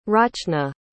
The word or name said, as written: rachna